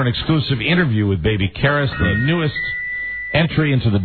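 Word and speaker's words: Carys: Karis. – Karis. – The pronunciation phonetically is K-E-H-R-I-S. – Oh, babies are so cute. – Karis.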